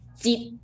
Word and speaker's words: Jeet: So just pronounce Jeet. Jeet is the sixth tone.